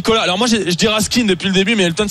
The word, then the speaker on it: Raskin: Ils m'ont expliqué, mais alors on dit Raskin, ok.